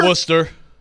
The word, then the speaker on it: Worcester: Worcester? Is that how we say this? I got fried yesterday for how I said it. Is it Worcester? – Worcester.